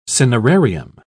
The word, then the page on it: cinerarium: British English